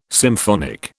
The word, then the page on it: symphonic: British English